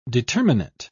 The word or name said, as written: determinate